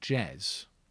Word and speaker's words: Jez: But for about 3 weeks, at which point enter a man called Jez, who'd never done any music before at all. Same man, a boy, same age. – Rhymes with Bez.